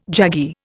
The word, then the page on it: jaggy: Indian